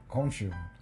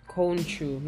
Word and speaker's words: Khonshu: Khonshu. – Khonshu, yeah.